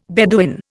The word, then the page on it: Bedouin: Indian